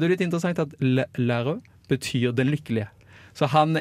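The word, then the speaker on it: Lheureux: Og Ingeborg, du må hjelpe meg med å uttale dette navnet. – Jeg ville sagt Lærø.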